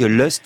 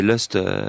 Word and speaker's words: Lust: Vous savez que Lust, c'est Lust, L-U-S-T. – Hein, ah, et pas Lost, c'est-à-dire qu'on n'est pas perdu pour la vie.